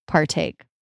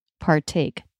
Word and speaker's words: partake: If the T is starting a stressed syllable, even when it's after an R before a vowel or diphthong, it's a true T because the stressed syllable rule is stronger. – Mm-hmm. – True T, like partake.